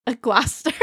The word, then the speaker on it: Gloucester: How do you pronounce Gloucester? – Gloucester. – Gloucester. – Gloucester. – The C silent.